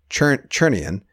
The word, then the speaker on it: Chernian: There's a silent T in that name. So Chernian.